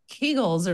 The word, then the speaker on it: Kegel: And because he's German, you would say Kegel.